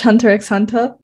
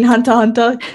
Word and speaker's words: Hunter x Hunter: Anyone here watch Hunter x Hunter? I mean, Hunter Hunter. – Well, either pronunciation's fine, man.